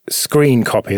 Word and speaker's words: scrcpy: Graham, stream Android with screen copy. That's what I'm going to call it, even though it's spelled S-C-R-C-P-Y. But that's got to be screen copy.